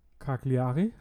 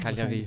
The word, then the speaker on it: Cagliari: Nick Preletz, ein weiterer Stürmer, der spielte insgesamt schon eineinhalb Saisonen in Österreich für WSG Tirol und ist ausgeliehen von Cagliari, habe ich das richtig ausgesprochen? – Cagliari. – Nicht. Noch einmal, bitte? – Cagliari. – Cagliari, nicht Cagliari, sondern Cagliari.